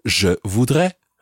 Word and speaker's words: je voudrais: The correct way to say this is je voudrais. Je voudrais. Um, I would like a coffee.